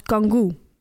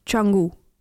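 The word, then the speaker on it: Canggu: Fanny sprak dit uit als kangoo, terwijl de C in het Indonesisch wordt uitgesproken als een ch-klank, dus changu.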